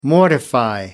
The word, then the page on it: mortifying: American English